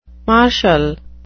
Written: martial

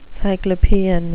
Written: cyclopean

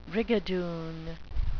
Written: brigadoon

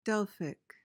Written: delphic

